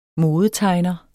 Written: modetegner

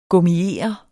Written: gummiere

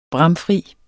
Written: bramfri

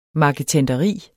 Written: marketenderi

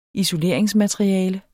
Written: isoleringsmateriale

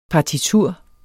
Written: partitur

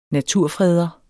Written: naturfreder

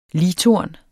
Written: ligtorn

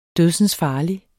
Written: dødsensfarlig

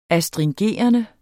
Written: astringerende

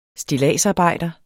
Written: stilladsarbejder

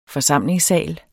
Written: forsamlingssal

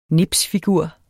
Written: nipsfigur

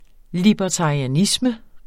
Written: libertarianisme

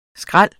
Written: skræl

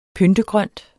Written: pyntegrønt

